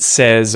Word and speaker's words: says: but when it is the third person, the vowel sound changes and it sounds like eh instead, okay? He says, she says, it says.